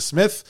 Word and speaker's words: Psmith: He has a book that I have in my library upstairs called Leave It to Smith with a silent P. And it's very P-S-M-I-T-H.